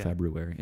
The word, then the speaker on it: February: I'm saying Feb-U, even though it's not how it looks phonetically. I'm sorry. It's how it phonetically sounds. It's not how it looks on paper, but everybody says February, so I'm not going to throw in that first R, yeah. February. It's a silent R.